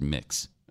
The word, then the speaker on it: Mx: My prefix, you know, like Mr. or Mrs. or Ms. My prefix is mix, MX. And then she puts in parentheses, pronounced mix.